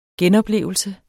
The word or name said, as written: genoplevelse